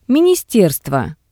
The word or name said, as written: ministerstvo